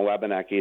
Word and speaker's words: Wabanaki: And the version that we're using right now, Wabanaki, is really more the anglicized version, although some of the tribal dialects do pronounce it more closer to that. From what I've been told and taught, you know, by folks like Roger Paul, Wabanakiik is more the term we would use in Passamaquoddy.